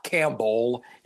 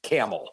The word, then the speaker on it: Campbell: Camel spelled like the soup. You say it like the animal. The P and B are left out. We do not want to hear that come out of your mouth. It is not Campbell. It's Camel.